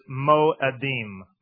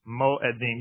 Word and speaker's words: mo'edim: The Hebrew word for feast is mo'edim. Mo'edim. You can write that in English. You spell it M-O-E-D-I-M.